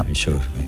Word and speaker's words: Verisure: Verisure.